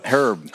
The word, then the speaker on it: Herb: Is it Herb or Herb? How would you prefer to be pronounced, Tom? – Herb. – Herb. Tom Herb joining us here, because it could be Herb, like a thyme or a rosemary.